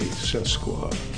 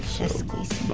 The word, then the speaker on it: sesquicentennial: Sesquicentennial. – We'll be right back. Sesquicentennial. – I thought it was an A, sesqua. – Sesquicentennial. – Not like Sasquatch.